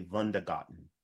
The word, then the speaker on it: Wundergarten: And it's actually not Wonder Garden. It's actually Wundergarten. – Oh, Wundergarten. – From the German pronunciation, Wundergarten.